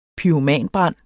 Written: pyromanbrand

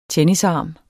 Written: tennisarm

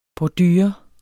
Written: bordure

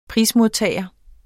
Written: prismodtager

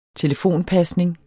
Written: telefonpasning

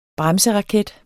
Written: bremseraket

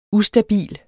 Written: ustabil

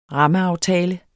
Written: rammeaftale

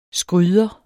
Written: skryder

